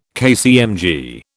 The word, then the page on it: KCMG: British English